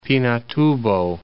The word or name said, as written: Pinatubo, Mount